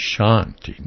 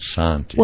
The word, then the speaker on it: Shanti: It's Shanti, not Santi. – Well, we pronounce it Santi, but in India they would say Shanti.